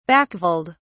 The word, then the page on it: backveld: British English